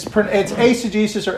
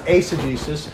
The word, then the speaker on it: eisegesis: EI, and it's asegesis or eisegesis, depending on how you. If you think of the letter eight or the number eight, EIGHT is A, so it actually be pronounced asegesis.